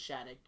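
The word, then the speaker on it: Shadyac: That's what I've heard it pronounced. – I've said Shadyk. I've heard it both, but I think it's Shadyk. You're right.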